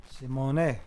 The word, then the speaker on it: Simon: Are you sure it's not Simon? – I'm sure. – It's spelled like Simon. – Simone. Simone. – Simone. – Simone. Guess in French.